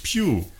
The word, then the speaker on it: Pugh: Ja, die spricht sich Pugh.